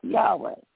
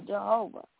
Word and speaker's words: Yahweh: We do not know exactly how to pronounce God's name. Most often, we will hear his name pronounced Yahweh or Jehovah.